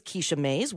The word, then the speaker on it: Kisha Mays: So today on Beyond Eight Figures, our guest is Kisha Mays.